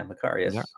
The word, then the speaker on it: Macarius: Yeah, Macarius.